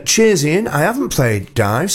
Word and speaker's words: DIIV: I listen to the show religiously, but I don't think I've ever heard you play anything by D-I-I-V, pronounced Dive, apparently.